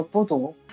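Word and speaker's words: 布 读 (बुद्ध): 然后到现在为止，我的卧室里面都有你的布达。这个小女孩是你吗？ – 这个小女孩是每一个人，所以叫我是布达。这个小女孩呢，也是我，就是我在画画的时候就是我，你在看她的时候就是你。布达是梵语的发音叫布读，它在印度的梵语里面是觉者的意思。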